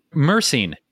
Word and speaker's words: myrcene: Myrcene? – Myrcene. Yes, myrcene. – Myrcene. Mm-hmm. Thank you. – Yeah. Absolutely. – What's that? – Myrcene, well, we can look it up and see what it does.